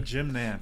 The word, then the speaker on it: Nantz: Jim Nantz, is that how you pronounce his name? – Yep, Jim Nantz.